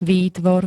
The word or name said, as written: výtvor